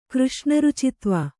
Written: ಕೃಷ್ಣರುಚಿತ್ವ

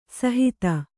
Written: ಸುಹಿತ

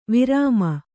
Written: ವರ್ಮ

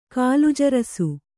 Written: ಕಾಲುಜರಸು

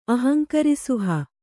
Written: ಅಹಂಕರಿಸುಹ

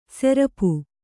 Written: ಸೆರಪು

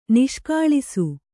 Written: ನಾಶಗೊಳಿಸು

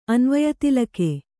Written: ಅನ್ವಯತಿಲಕ

